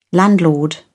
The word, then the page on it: landlord: British English